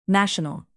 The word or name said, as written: national